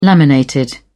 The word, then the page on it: laminated: British English